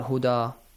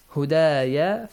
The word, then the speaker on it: هُدَايَ: It's not هُدَى. هُدَايَ فَلَا خَوْفٌ.